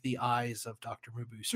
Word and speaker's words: Mabuse: And Loman is like the hero who fights him in his second appearance because uh, Mabuse or Mumbu, I used to say Mabuse, it's Mabuse. I've been saying it wrong for years.